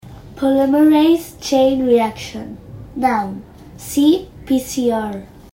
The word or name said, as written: polymerase chain reaction